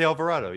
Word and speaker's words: Alvarado: Alvarado, I think is how you pronounce it. I'm pretty sure. – Mm-hmm. – But nevertheless, like that dude. – Oh, Jose Alvarado.